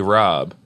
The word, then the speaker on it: Rabe: Well, and I think it's pronounced Broccoli Rob.